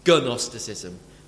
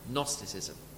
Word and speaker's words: Gnosticism: Spelled G-N-O. Gnostic. You'd think it was spelled, it sounded Gnosticism. But actually it's pronounced Gnosticism. It's just like got a silent G at the front of it.